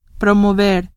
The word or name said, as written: promover